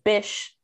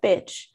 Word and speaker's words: Bish: And then Boss Bish is Bish, not Bitch, BAWSEBISH. Bish is like something that we used to say maybe in like the 2000s, like Bish. It's not. People always mispronounce that.